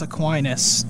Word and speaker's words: Aquinas: St. Thomas Aquinas, did I say that right? – Yes.